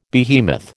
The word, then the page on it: behemoth: American English